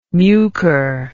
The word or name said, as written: mucor